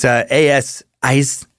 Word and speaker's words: Aisne: And I'm not sure about the pronunciation of that river. I looked up most of these words, but there was a fucking million words this week. Too many fucking countries. But it's Aisne River, A-S-N-E, for you pronunciation sticklers.